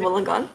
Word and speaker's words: Wollongong: I mean, as far as your work, and you know, you work in, I hope I'm pronouncing this correct, Wollongong? – That's right, in Wollongong, yes, Wollongong.